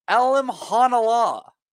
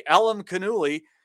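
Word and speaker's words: Alimkhanuly: And it turns out his name is pronounced Alimhanala, okay? So all that effort that we put in trying to say Alimkanouli.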